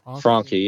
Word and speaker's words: Franke: How do you pronounce it? – Franke.